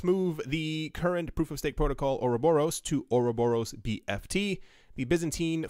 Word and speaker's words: Ouroboros: I thought it was Ouroboros and then Ouroboros. I learned how to say this as Ouroboros, so it will be said as Ouroboros.